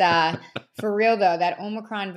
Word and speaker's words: Omicron: So it's getting a little scary with this Omicron. Am I saying it right? – Yeah, Omicron Greek, variant.